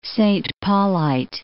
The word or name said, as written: Saint Paulite